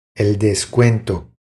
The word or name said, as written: El descuento